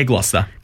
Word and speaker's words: Gloucester: Well, second was Gloucester. Is that how you say it? – Yeah, Gloucester.